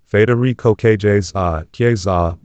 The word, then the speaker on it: Federico Chiesa: Federico Chiesa, Italian pronunciation, Federico Chiesa,